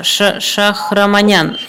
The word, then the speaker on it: Шахраманян: Самвел Шахмарян, Шахраманян, извините, пожалуйста.